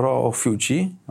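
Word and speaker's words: Ophiuchi: I jadąc z taksówce uświadomiłem sobie, że nie wiem, jak się to powinno wymawiać, dlatego że jak mówisz po angielsku, to Amerykanie czytają to jako ro, ro fiuci. Okej. Okay. A, a to jest nazwa łacińska, więc ona powinna być chyba o fiuki czytana.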